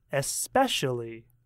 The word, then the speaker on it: especially: Listen to how I say it. Especially. Especially. It has 4 syllables when I speak, and the stress is on syllable 2.